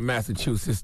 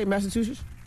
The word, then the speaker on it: Massachusetts: Yes, former governor of Massachusetts, Deval Patrick. – Say that. Say it again. From where? – Former governor of Massachusetts. – Is that how you say Massachusetts? Massachusetts.